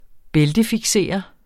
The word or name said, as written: bæltefiksere